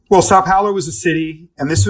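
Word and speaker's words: São Paulo: And the city of, say, I don't know if I'm saying this right, Sao Paulo? – Sao Paulo, Brazil, yeah.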